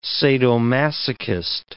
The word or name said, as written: sadomasochist